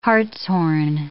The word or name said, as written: hartshorn